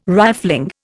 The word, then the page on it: rifling: Indian